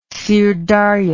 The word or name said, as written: syrdarya